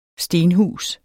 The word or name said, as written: stenhus